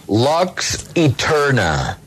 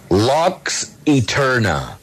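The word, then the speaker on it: Lux Aeterna: Lux Eterna. Lux Eterna.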